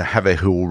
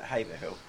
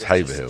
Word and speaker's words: Haverhill: And also in Great Yarmouth today, they go to Haverhill Rovers. Great Yarmouth go to Haverhill Rovers today. Right, so... – we pronounce it Haverhill. – Haverhill, sorry. – Yeah, oh, sorry, I'm being told it's Haverhill.